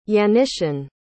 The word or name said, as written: yanisin